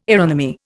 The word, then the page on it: aeronomy: Indian